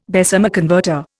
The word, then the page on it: Bessemer converter: Indian